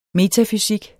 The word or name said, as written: metafysik